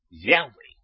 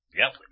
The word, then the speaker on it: Yahweh: Espero que todos ustedes ya conocerán lo que es Baruch Hashem, Yahweh, ¿no? Baruch, bendito. Hashem, el nombre. El nombre siempre ha sido, es y será Yahweh. Sin acento en la E. Porque si decís Yahweh, ya no es el nombre.